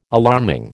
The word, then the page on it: alarming: American English